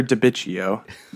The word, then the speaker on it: DiBiccio: I'm Victor DiBiccio.